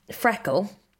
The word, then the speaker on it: FRKL: So I launched Freckle, which is spelt F-R-K-L, but pronounced Freckle, just over a year ago, a year and a few months.